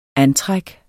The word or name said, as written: antræk